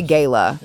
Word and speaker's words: gala: Apparently the Met Gala was last night. I don't know how do you say it? – Both tomato, tomato. – I usually say gala. – Yeah, gala, American.